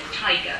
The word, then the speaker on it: taiga: The famed Siberian tiger, I hope you, that's how you say it, T-A-I-G-A, and be a rich source of folklore and folk song with which he would enrich his later poetry.